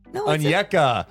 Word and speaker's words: Onyeka: Aneku Okongwu. – Okongwu! – And I think I butchered the pronunciation of his last name. – Okongwu? – I did what I haven't been doing. Onyeka. Onyeka! Okongwu.